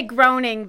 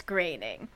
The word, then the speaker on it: Groening: He said Matt Groening, it rhymes with complaining. That's an actual quote from Matt Groening. – So people say groaning, but it's graining.